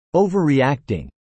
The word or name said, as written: overreacting